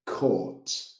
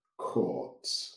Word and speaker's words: caught: ¿Estos cómo se pronuncian? ¿Como catch o como buy? – Yeah, es verdad, Malina. Eso es un desafío. So, catch, caught, caught. – Vale, la GHT no se oye.